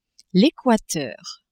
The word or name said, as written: Equateur